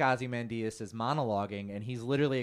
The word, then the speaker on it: Ozymandias: In comics, how do you say that, Danny? – It's Ozymandias.